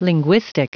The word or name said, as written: linguistic